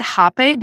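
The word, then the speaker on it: rapé: but the Reipe. How do you pronounce it? I've done it. – Yeah, so you pronounce it Hape, but it's spelled R-A-P-E with an apostrophe on the E.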